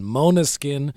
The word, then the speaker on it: Måneskin: N-E-S-K-I-N. Måneskin,